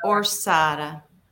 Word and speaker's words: Orsara: Is that how you pronounce that? Orsara? – Orsara.